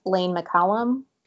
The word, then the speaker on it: Lane McCollum: but because he is technically a safety, I will nominate Lane McCollum. Is that how you say his name? I've never even heard of him before. – Yes. Yes, that's how they're saying it.